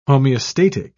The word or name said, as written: homeostatic